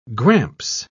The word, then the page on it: gramps: British English